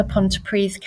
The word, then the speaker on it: Pontypridd: Um, I'm on the Pontypridd campus, and the town is Pontypridd. Um, it's spelt Pontypridd, um, but in Welsh, a double D is a TH.